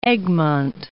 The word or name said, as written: Egmond